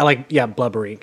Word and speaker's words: Blubrry: But it's spelled like blueberry. – Yeah, blubbery, kind of. It's like B-L-U-B-R-R-Y.